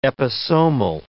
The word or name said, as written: episomal